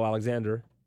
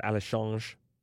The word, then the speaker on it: Alexandre: Faces Cosmo Alexander, or Alexandre, if you want to give the Brazilian pronunciation.